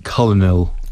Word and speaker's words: colonel: Colonel. Okay. – Pisze się colonel, ale mówi się colonel.